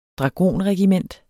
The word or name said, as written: dragonregiment